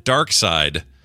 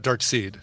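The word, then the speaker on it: Darkseid: Just a quick note to say I found multiple sources that confirmed Jack Kirby himself pronounced it Dark Side. He says it is a Germanic pronunciation of the villain's name. – So actually Dark Side, not Dark Seed.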